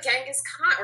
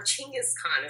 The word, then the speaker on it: Chinggis Khan: Yeah, he looked like Genghis Khan, or Chinggis Khan is the correct pronunciation of his name.